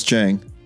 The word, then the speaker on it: Chheang: Chang is the English pronunciation for the Cambodian actual pronunciation of Chheang. Two H's, silent E. So. – Yeah. I did not know it was Chang.